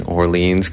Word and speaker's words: Orleans: And I grew up just down Hinman Settler Road from the town of Orleans in Orleans County. And it's pronounced Orleans.